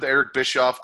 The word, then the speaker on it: Bischoff: Yeah, well, we know that now, but right now it seems like, especially with Eric Bischoff on Monday Nitro doing commentary. I didn't fuck it up that time. – You said it right.